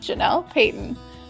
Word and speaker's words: Janelle Payton: So many people actually hesitate on my name, but it's said exactly how it's spelled, Janelle Payton.